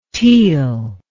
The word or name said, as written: teil